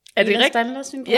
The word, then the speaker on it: Ehlers-Danlos: Ehlers-Danlos-syndrom? – Ja, ja. Ja, det er rigtigt udtalt.